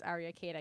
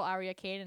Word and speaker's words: Aria Khaide: You can find me on many vids, Aria Khaide. I keep it really simple. Clips for sale, Pornhub, Model Hub. Honestly, you just Google Aria Khaide and I'll pop up.